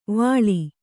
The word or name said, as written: ವಳಿ